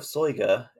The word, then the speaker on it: Soyga: The Book of Soiga, and yes, that is a pronunciation.